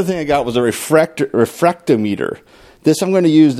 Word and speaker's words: refractometer: It is refractometer. It sounds like a made-up word, but that's what it's called.